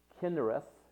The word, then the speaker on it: Chinnereth: And it was called, and it looks like Chinnereth, but it is pronounced Kinnereth, and it means harp.